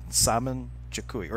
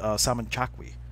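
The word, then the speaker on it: Samin Chequi: But so, like, spell it how you would remember to say it. Saman Chikui, or Sami Chakui. But it's also pronounced, like Elizabeth pronounced it, Saman Chakui.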